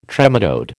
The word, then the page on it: trematode: American English